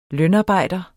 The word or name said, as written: lønarbejder